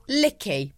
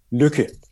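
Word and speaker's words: Lykke: So we pronounce it Luke.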